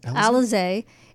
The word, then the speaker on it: Alizé: Alizé, is that how you say it? – Alizé is an award-winning blend of French vodka, because they're known for their vodka, and cognac, infused with exotic natural flavors.